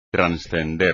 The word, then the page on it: transcender: espagnol